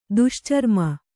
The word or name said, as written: ದುಶ್ಚರ್ಮ